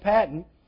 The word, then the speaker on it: Paton: It's spelled where you might think it's pronounced Patton, P-A-T-O-N, but I've always heard it pronounced Patton.